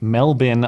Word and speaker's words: Melbourne: Melbourne, Australia.